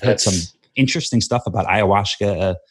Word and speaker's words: ayahuasca: Ashwagandha? Ayahuasca? Ayahuasca. That's it.